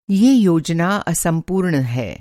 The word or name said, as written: असम्पूर्ण